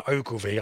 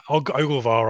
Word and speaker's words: Ogilvie: Yeah, it was it was Sean Williams who went off, and it was Connor Ogilvie. I was enjoying your pronunciation there. – What did I say? Og- Ogilvar or something.